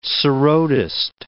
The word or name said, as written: sarodist